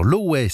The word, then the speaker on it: Lowey: Une nouveauté maintenant, c'est le chanteur Lowey. Ça s'écrit L-O-W-E-Y.